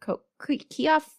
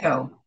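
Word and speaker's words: Keough: Is it Kioff? I'm so bad at that. – Kyo.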